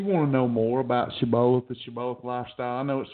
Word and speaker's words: Shibboleth: Shibboleth is the right pronunciation, just in case I got some Bible folks out there.